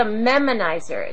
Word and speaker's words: memonizer: I want to know more about this process because this, you called it a nemonizer, I believe it was, and excuse me if I'm mispronouncing some of these things. – It's a memonizer. It's memonizer, okay. – M-E-M-O-N-I-Z-E-R.